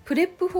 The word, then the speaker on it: PREP: はいそれではあの文章の構成方法として今日お伝えしたいのがプレップ法というものなんですよ聞いたことありますかプレップ法っていうのは PREP でプレップって読むんです